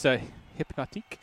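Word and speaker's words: Hpnotiq: You got to roll the R. – It's Hypnotique.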